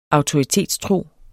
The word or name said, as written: AUTORITETSTRO